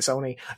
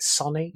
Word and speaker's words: Sony: And it was just a guy who used to work at Sony. He always pronounced it Sonny. – Oh. – It really pissed me off.